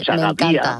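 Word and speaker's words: Ochagavía: Hombre, no sabía eso, a que era uno de tus lugares favoritos, pero Chagatía, con acento en la I, para destruir el diptongo, es un pueblo maravilloso.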